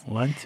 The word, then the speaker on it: Лантимос: Кстати, ударение на А. Лантимос. – Лантимос.